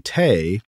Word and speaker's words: thé: The French language differentiates between un thé, rhymes with bay, made with Camellia sinensis, and un tisane, made with other plants.